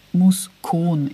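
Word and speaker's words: Muscon: Also, Entschuldigung, ich habe Moskul gesagt. Ich meinte Moskul. Muskon ist die richtige Aussprache, es tut mir leid.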